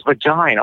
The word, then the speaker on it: vagina: And then I kept saying Virginie for some odd reason. And then Jessica said, "it's not Virginie, it's vagina." I'm like, oh, shoot, that makes sense now.